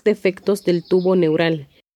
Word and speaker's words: neural: Los defectos del tubo neural. – And the word neural in Spanish has a diphthong that can be hard to pronounce. Neural.